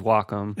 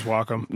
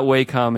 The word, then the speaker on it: Wacom: here's a keyboard, here's a futuristic pen that's like a Wacom pen, which, yeah, we discovered the real pronunciation is Wacom. – It is Wacom. – Not Wacom and definitely not Wacom.